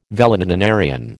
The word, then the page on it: valetudinarian: American English